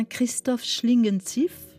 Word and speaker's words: Christoph Schlingensief: Christoph Schlingensief, on le prononce comme ça? – Oui.